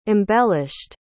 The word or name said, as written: embellished